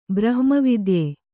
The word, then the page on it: ಬ್ರಹ್ಮವಿದ್ಯೆ: brahma vidye